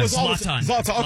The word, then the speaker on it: Zlatan: Zoltan. – Yeah, I used to call him Zoltan. – Zlatan. Okay. Zlatan.